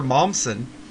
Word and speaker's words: Momsen: She was a little girl, and that's Taylor Momsen. I'm probably saying her last name wrong, but dang. – I think you're saying it right.